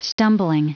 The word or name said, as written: stumbling